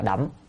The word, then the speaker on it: đổng: Ở đây chúng ta phải đọc là đổng. Đổng là thấu suốt.